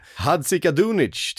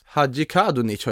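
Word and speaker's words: Hadžikadunić: Vi har också ska vi se här, Hadzika Dunic till Mallorca. Officiellt. – Hadji Kadunic har jag hört att det uttalas. Rätta mig om jag har fel.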